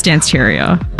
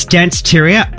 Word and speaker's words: Danceteria: Have I said that right? I think it's Danceteria. – Oh, you're 100% correct. It's Danceteria.